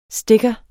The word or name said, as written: STICKERE